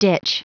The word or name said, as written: ditch